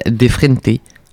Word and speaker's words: De frente: Le morceau s'appelle Defrente de frentes. – De frente. J'imagine que ça se prononce euh, espagnol. al espagnol, sí.